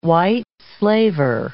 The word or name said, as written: white slaver